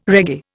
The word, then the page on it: reggae: Indian